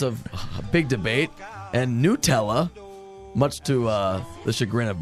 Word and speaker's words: Nutella: If the company says you pronounce their name Nutella, that's how it's pronounced.